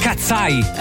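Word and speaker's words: KATSEYE: Eh sì, si legge Cats, gatti, occhi, gli occhi di gatto, quindi cazzai. Così, raga, si chiama così, dai, con la musica, vai, facciamo finta di niente.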